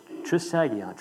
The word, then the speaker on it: Trisagion: However, the first syllable, as correctly defined, the pronunciation is tris. So it's trisagion, trisagion, not trisagion, trisagion.